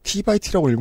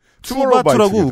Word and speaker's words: 투바투: 기본 예의 T by T라고 읽으면 안 되죠. TXT 혹은 투바2 한글식으로 읽는 게 낫군요. 네. – 투바투라고 웃기게 네.